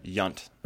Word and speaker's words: Yount: His last name is what? – Yunt. – Oh. What the? – I know. – We talk about that a lot. It's spelled. – Because it's spelled Y-O-U-N-T.